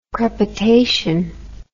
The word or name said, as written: crepitation